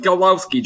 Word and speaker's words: Gawlowski: G-A-W-L-O-W-S-K-I, as it is Brendan Golowski joining me right here on the podcast.